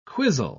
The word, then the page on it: quisle: British English